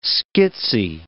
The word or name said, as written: schizzy